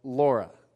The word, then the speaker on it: Lara: So my wife's name is spelled L-A-R-A, but she doesn't like to be called Lara. She likes to be called Laura, okay?